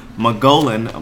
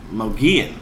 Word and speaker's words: Mogollon: because I was looking at it and I said the Mogollon monster, but it's actually the Mogollon, based out of the Mogollon Rim, which is, like, this foresty area.